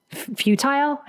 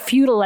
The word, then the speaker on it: futile: Futile? I never knew how to pronounce that word. F-U-T-I-L-E. Not feudal as in like feudal lords, but futile.